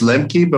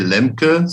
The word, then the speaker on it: Lemke: Yes, when we moved, my name is Lemke, but when we moved here, my wife told me I should say Lemke so that everyone knows exactly how to spell it.